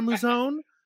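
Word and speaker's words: Mouzone: Is that how you say it, Brother Muzone? – That's right.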